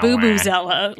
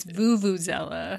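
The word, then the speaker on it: vuvuzela: It's not Boobazella, like Boo Boo the Bear. It's Vuvuzella. – Oh. – Like, I've been saying it wrong my whole life. – Your whole life. You were today years yeah. old when you realized it was a V, not a B.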